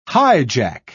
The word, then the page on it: highjack: British English